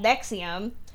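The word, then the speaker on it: NXIVM: So Allison Mack was part of this cult called Nexium, which is spelled stupid. – Oh, is that how you say that? – Yeah, it sounds like the heartburn medication.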